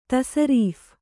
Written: ತಸರೀಫ್

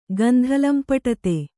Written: ಗಂಧಲಂಪಟತೆ

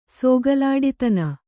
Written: ಸೋಗಲಾಡಿತನ

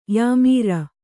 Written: ಯಾಮೀರ

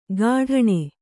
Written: ಕಠಾಣೆ

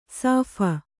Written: ಶಫ

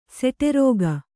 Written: ಸೆಟೆರೋಗ

